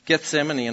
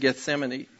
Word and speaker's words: Gethsemane: Gethsemane, and I knew that too. Named Gethsemane.